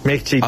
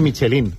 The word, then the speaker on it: Michelin: Michelin. A ver, aquí en la península ibérica decimos Michelin.